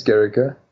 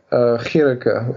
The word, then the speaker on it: Gericke: I would say both are right, because the original German is Gericke. And the Afrikaans people say Gericke with a G. That is more from a Dutch and other background.